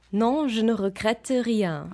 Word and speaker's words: Non, je ne regrette rien: Non, je ne regrette rien.